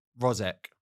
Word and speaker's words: Mrozek: Fabian Rozek. I'm guessing it's silent M. Rob, looking at you approvingly.